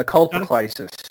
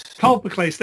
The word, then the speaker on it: Colpocleisis: Colpocleisis? – Yeah, Colpocleisis. – Colpocleisis, there you go.